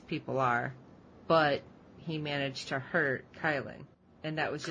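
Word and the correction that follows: Khlyen: I always pronounced it that way too, until they started pronouncing his name as Klein on the show. So I have to remember when I'm typing up the recap that it's Klein, not Kylan.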